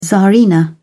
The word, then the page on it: tsarina: British English